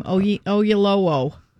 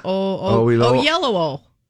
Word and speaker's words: Oyelowo: There will be a second season, and it will revolve around real-life lawman Bass Reeves with David how do you say his name? Oyelowo. Remember, he was in that other movie, yeah. – David Oyelowo. – Oh, yes, that's it. Yeah, that's it.